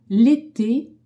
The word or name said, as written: ete